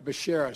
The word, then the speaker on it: Becerra: Javier Becerra, excuse me. – No, still wrong. Let's try it again, Mr. President. – For Secretary of Health and Education Services, I nominate Javier Baccarilla. Javier Becerra, excuse me.